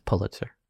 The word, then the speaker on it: Pulitzer: Because it's pronounced Pulitzer.